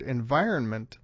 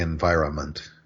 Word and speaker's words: environment: So we talked about pundit, but in this word, environment, the N tends to get dropped in pronunciation.